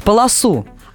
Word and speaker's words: полосу: Нам надо строго говорить полосу. А вот сотрудникам ГИБДД и сотрудникам других организаций позволяется варьировать ударение в слове «полоса».